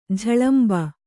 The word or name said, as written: ಝಳಂಬ